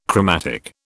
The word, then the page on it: chromatic: British English